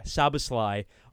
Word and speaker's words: Szoboszlai: Szoboszlai on the right striker, and then he played Gakpo at the left striker.